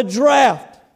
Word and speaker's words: draught: draught. All right, I know it looks like drought, but it says draught. And Alexander Scoresby, he clears that up for me. Draught, that's how you pronounce it.